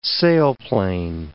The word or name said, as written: sailplane